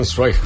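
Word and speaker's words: Zweig: Zwick, Zwick? – Zwick.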